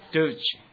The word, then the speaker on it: Deutsch: 因为那个德文是怎么念他们国家的？你们懂得吧？Deutsch 啊，是德意志哈、啊。